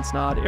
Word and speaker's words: Snod: My name's Ryan Snod, it rhymes with odd, and I'm out.